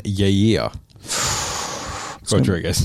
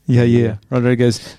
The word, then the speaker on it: Yair Rodriguez: We got Holloway and Yair Rodriguez. – Good pronunciation. – Yair Rodriguez. That's the main card that's coming up next.